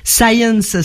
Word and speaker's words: Sayens: Science, ça s'écrit, ça se dit comme la science en anglais, mais voilà. Ça s'écrit S-A-Y-E-N-S.